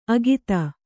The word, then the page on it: ಅಗಿತ: Kannada